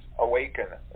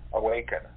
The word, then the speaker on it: Aweyken: It's Awaken. It's spelled A-W-E-Y-K-E-N, Awaken.